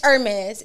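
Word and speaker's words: Hermès: It's Hermes. It's no H. – Hermes. – Yeah, it's no H, guys. – So what's the H silent? – For? – The H is silent.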